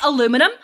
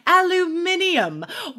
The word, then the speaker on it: aluminum: I say aluminum, but a British person would say aluminium, which I love.